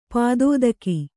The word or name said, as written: ಪದಾತಿಕ